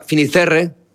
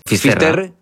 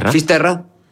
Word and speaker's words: Fisterra: Entonces llegó a Finisterre. ¿Se dice así? – Fisterra. – Fisterra. Es como se ponen los gallegos también, en fin.